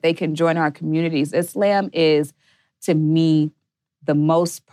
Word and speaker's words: Islam: So it's pronounced Islam. – Islam. Well, yeah, but you know, we have different pronunciations, but if you're doing in Arabic, it would be like Islam.